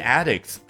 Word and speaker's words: addict: As the two chains battle for caffeine addicts' loyalty in the market，表达非常的有意思。两家咖啡连锁企业去争抢什么呢？咖啡因上瘾者的忠诚。你看，我们这样的人叫 caffeine addicts，注意一下读音 addict 啊。如果要表达 I am addicted to caffeine，用那个形容词的形式，重音是不一样的。